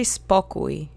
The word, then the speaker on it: spokój: The first word that comes to mind is spokój, which means the state of calmness or peace. Let's say spokój together three times. Accent is on the first syllable.